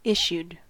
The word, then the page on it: issued: American English